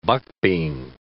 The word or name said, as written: buckbean